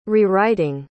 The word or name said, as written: rewriting